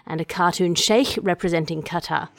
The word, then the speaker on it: Qatar: Sorry, it's pronounced Qatar.